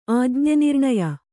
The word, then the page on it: ಆಜ್ಞೆನಿರ್ಣಯ: kannada